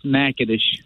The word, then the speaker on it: Natchitoches: Yeah, you're close. It's Natchitoches.